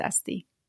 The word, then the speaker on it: ST: So I cut it down to just S and T. It's pronounced the same, so I might as well do it. So yeah, ever since then, it's stuck, and now everyone knows me as Esty.